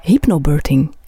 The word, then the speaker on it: hypnobirthing: Je schrijft in je boek over hypnobirthing. Mhm. Spreek je dat juist uit? Ja.